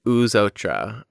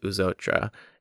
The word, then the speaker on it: Eux Autres: It's Eux Autres,